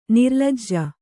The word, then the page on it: ನಿರ್ಲಜ್ಜೆ: nirlajje